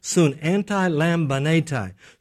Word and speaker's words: antilambanetai: The word that's used in the Greek for helpeth, this is a hard word to pronounce, but it's soon antilambanetai. Soon antilambanetai. Soon, it comes from three words. Soon, anti, and lambano.